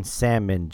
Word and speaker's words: salmon: Just forget the L. It's there for decoration. – It is there for decoration. So his name is Jovan Salmon Johnson.